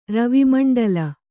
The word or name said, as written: ರವಿಮಂಡಲ